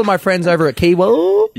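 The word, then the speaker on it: Kiewa: Now, some people say Kiwa. Some people say Kiwar. I personally say Kiwa. – Oh, I think you say it right, actually, now. Has he finally learned how to say Kiwa? – Hey.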